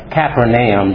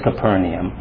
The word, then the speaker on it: Capernaum: She wouldn't get up in the congregation, but she would come up to me afterwards and she said, well, you know, that isn't Capernaum, that's Capernaum.